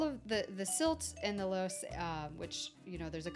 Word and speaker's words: loess: Silt and loess are gonna, I don't know if I'm saying that right, loess. – I think I have a pronunciation here. It's luss, L-U-S-S.